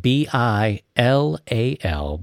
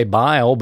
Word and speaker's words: Bilal: It's spelled B I L A L, Bilal. I want to say bile, but that isn't right.